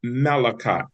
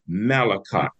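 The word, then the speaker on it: Melachot: And it's pronounced Malachot. M-E-L-A-C-H-O-T. M-E-L-A-C-H-O-T. Malachot.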